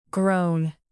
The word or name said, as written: groan